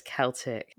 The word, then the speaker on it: Celtic: Did you just say Celtic instead of Celtic? – I thought it's pronounced Celtic. – No, it's Celtic. – Well, shit. – Celtic is a hockey team.